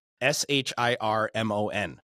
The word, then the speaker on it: Shirmon: And when she introduced herself to me, it took me like a month to get her name right. And I'll, and it's just Shirmon. But because it doesn't look like Shirmon when she writes it.